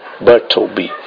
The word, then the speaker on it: Bartleby: E essa obra que nós vamos trabalhar hoje, que é Bartleby, essa é a pronúncia correta.